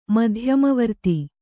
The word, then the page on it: ಮಧ್ಯಮವರ್ತಿ: madhyama varti